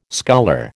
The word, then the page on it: sculler: American English